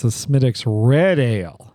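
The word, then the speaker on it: Smithwick's: So for you people who don't know, you pronounce it Smittix, but if you looked at it, you know, it would say Smith Wicks, and that's like not the right way to say it. It's Smittix.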